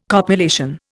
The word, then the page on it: copulation: Indian